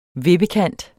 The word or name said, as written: vippekant